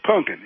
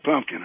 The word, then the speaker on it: pumpkin: And by the way, you're mispronouncing pumpkin. It's pumpkin. It's not pumpkin, okay? – Okay.